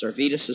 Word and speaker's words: Servetus: Servetus is spelled S-E-R-V-E-T-U-S, Servetus.